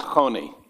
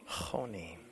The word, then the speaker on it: Choni: It's pronounced Choni. – Choni. Okay.